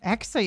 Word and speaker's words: Exa: Is that how you say it? Exa? – Exa. E-X-A.